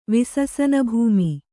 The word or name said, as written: ವಿಸಸನಭೂಮಿ